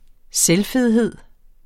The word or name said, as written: selvfedhed